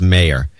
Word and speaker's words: Mayer: And it's Meyer, M-A-Y-E-R, is pronounced Mayor.